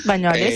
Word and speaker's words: Bañolas: Sí, nosotros arrancamos en Bañolas, corríjame ustedes por ahí, pronuncio mal, creo que es Cataluña. – Bañolas, sí.